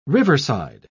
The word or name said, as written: riverside